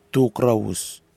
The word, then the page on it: tukrawhus: Nafe